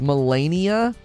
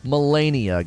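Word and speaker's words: Malenia: It's pronounced Melania. Melania, great. Well, I just did an open and a recording with me saying Melania. Melania, Melania.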